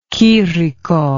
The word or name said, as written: chirico